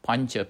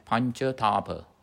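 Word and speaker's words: pañcatapa: and he one of the things that he practiced was pancha P-A. In diacritical mark, tilde on top, pancha, like signora, pancha. Pancha is five, tapa, T-A, long A, P-A, tapa. Pancha, tapa.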